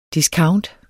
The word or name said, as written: discount